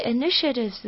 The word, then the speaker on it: initiatives: 接下来我们要来谈谈的是 tia 这边中间的发音，tia 在 initiatives 里面呢是发 sh 的音，所以念起来是 initiatives。